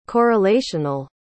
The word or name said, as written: correlational